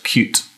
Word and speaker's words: Qt: So is it Qt, or I don't know how you pronounce that one. – Well, I think it's pronounced cute.